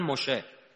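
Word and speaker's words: موشه (משה): میدونی دیگه عبری ها سه و شه تلفظ میکنن عمدتاً. موسی را میگن موشه، سلام را میگن شالوم، سلیمان را میگن شلمان، نسا زنا را میگن نشا.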